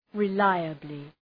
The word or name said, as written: reliably